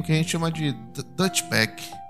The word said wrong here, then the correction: douchebag: Douchebag, como é que, qual é a pronúncia, professor? – Douchebag.